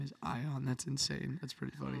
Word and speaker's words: Ion: Ion is how you say his first name. Ion.